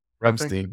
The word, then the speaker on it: Ramstein: Then he went to, I always say this wrong, Ramstein. – Ramstein, yeah.